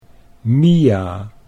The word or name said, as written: Mia